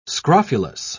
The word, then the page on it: scrofulous: British English